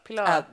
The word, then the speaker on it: Pilar: How do you say Pilar? Pilar. – Like that, yeah. I think.